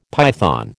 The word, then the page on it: python: American English